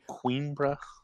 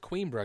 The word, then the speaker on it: Coimbra: And I told Eric, I go, I believe it's pronounced Coimbra because I was reading the forums and somebody had typed that in there.